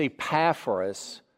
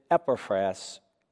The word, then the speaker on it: Epaphras: His name is Epaphras, though some pronounce it Epaphras. Either will do. I'll use Epaphras.